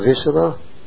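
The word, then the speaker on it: Vassula: And I say this not knowing anything of Visala. Right, I think it's pronounced. Am I right? You are right.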